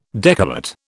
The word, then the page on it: decollate: British English